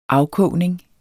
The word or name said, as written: afkogning